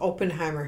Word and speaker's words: Oppenheimer: Robert Oppenheimer.